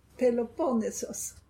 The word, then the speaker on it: Peloponnesos: Hörde ni att hon uttalade som en grek? Vi svenskar, vi uttalades fel. Du får säga igen, det kanske inte var alla som observerade det. – Peloponnesos.